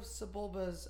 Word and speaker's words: Sebulba: It's not Sebulba. – No, Sebulba. – It's Sebulba. – It's actually Sebulba. Exactly the way it's spelled.